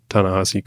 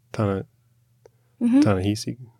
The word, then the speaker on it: Ta-Nehisi: And it looks like they might use some of the Ta-Nehisi coats. Ta-Nehisi.